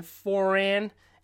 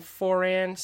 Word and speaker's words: Foran: I do, again, want to stress that my name is Ryan Foran. It is not Furin, as the voiceover pronounced. It's a little fast. So most of you will know me as Ryan Foran.